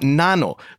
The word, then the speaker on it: N4NO: But tell us about Nano. So it's spelled N4NO, pronounced Nano.